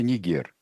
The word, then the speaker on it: Нигер: Правильное ударение в названии страны, где бы что ни писалось, это Нигер.